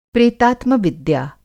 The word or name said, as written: प्रेतात्मविद्या